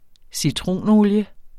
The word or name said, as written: citronolie